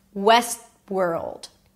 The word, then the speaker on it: Westworld: So I don't really pronounce the T. It's not west. West. Moving on to the next word. World. West world.